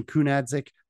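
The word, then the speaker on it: Kunaszyk: You mentioned the boy, Jordan Kunadzic, I believe is how you pronounce that.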